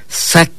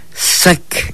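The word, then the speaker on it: sak: Sac. Sac. Escúchelo. ¿Cómo suena? Sac. Esto es el color blanco en lengua maya.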